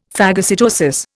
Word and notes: phagocytosis: Indian

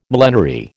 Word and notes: millenary: American English

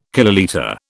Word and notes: kilolitre: British English

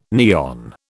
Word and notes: neon: British English

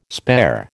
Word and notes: spare: American English

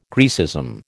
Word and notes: Grecism: American English

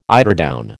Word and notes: eiderdown: American English

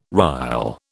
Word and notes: rille: British English

